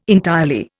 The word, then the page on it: entirely: Indian